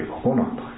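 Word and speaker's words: 心奏: そしてよりこさんのお孫さんは、心を奏でると書いてココナと、とてもいい名前ですね。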